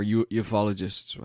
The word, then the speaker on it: ufologist: Correction there, and it's based on a book by a guy called John Keel, who's one of the preeminent UFOologists or ufologists, however you pronounce that.